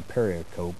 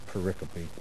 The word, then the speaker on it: pericope: It's P-E-R-R-I-C-O-P-A-E, and a pericope is a section of scripture, but learn to pronounce that correctly. It is not a pericope, it's a pericope.